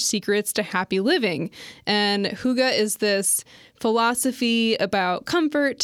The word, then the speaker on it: Hygge: It's spelled H-Y-G-G-E. And it's a Danish word. – Okay. – But yeah, I guess you're supposed to say Hygge. – Okay. Oh, Hygge. I'm supposed to say Hygge? – Well, that's how you say it.